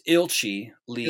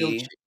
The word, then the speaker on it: Ilchi Lee: When you Google around on the organization, the leader, Ichi Lee, is that how it's pronounced? I've only seen It's Ilchi Lee. Ilchi.